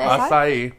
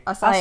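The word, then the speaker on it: açaí: How do you say? – Acai. – Acai. – Acai. – That one. – Acai. – So Acai. – Yep, yep. – Yeah, it's like Asia Akia.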